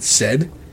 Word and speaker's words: Said: His last name was Saad, but he pronounced it Said.